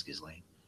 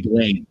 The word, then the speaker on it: Ghislaine: Or is it Ghislaine? I think it's Ghislaine. Yeah, Ghislaine. – I've heard it pronounced all kinds of different ways.